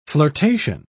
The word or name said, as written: flirtation